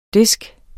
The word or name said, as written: DISC